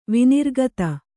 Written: ವಿನಿರ್ಗತ

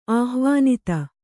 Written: ಆಹ್ವಾನಿತೆ

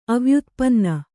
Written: ಅವ್ಯುತ್ಪನ್ನ

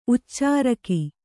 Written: ಉಚ್ಚೆರೋಗ

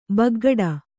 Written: ಬಗೆಗಿಡು

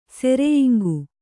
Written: ಸೆರೆಯಿಂಗು

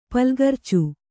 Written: ಪುಲ್ಗರ್ಚು

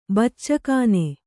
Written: ಬಚ್ಚಗಾನಿ

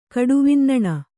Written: ಕಡುವಿನ್ನಣ

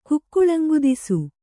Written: ಕುಕ್ಕುಳಂಗುದಿಸು

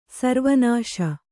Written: ಸರ್ವನಾಶ

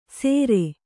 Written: ಸೆರೆ

